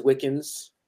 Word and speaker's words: Wickens: My last name is Wickens, not spelled like Wiccan, but it's close enough.